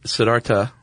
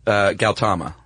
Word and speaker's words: Siddhartha Gautama: Actually, Siddhartha... – Okay, so the H is silent? – Gautama. – Nice.